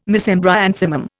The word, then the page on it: Mesembryanthemum: Indian